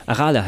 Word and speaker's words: Arale: Was mich, was mich bei der deutschen Anime-Serie gestört hat, ist wieder dieses typische, wir müssen jeden Namen auf der letzten Silbe betonen. Arale. Hallo Arale. Arale heißt das, verdammt.